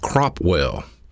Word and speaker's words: Cropwell: It's pronounced Cropwell. C-R-O-P-W-E-L-L, Cropwell, Alabama.